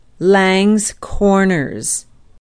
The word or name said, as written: Langes Corners